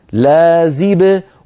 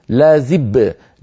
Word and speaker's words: لازب: الأمر الثاني أخي، القلقلة مخففة تخرج بالتباعد بين طرفي عضو النطق هكذا، حيد الكواكب لازب، ولا تقول الكواكب لازب، لازب صار كأنه باين، أو لواحد صار كأنه دالين، فهذا فيه تثقيل للحرف وكأنه مضاعف وكأنه مشدد.